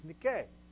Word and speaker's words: Nike: Correctly pronounced is Nike, which means victory.